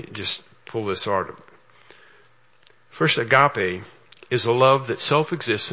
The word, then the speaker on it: agape: It doesn't say God knows love, and when it says God is love, it's agape love. It's an E. A long E, agape love.